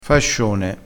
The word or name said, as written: fascione